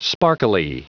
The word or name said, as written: sparkly